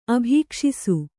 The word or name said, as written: ಅಭೀಕ್ಷಿಸು